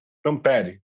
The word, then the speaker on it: Tampere: É, eu tô falando certo o nome da cidade? Tampere. Tampere. E, na Finlândia.